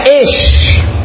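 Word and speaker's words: esh (אש): Homem em hebraico é ish. E fogo em hebraico é esh. É próximo ou não? Ish e esh.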